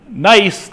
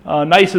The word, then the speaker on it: gneiss: So gneiss, that's spelled with a G, gneiss is a typical example of a metamorphic rock that typically has its origin in granites.